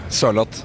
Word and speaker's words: Sørloth: What about Sørloth? How are you supposed to say? Sørloth? How do you say that? – A bit slower. Sørloth. – Sørloth.